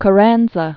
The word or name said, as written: Venustiano Carranza